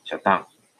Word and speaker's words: 遮断: 那念作小当。